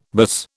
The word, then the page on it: buzz: British English